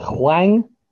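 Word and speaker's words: Hwang: I've just gone through the transfers and I've just noticed that I don't know if I'm going to say his name right, but is it Huang from Wolves? – Yep.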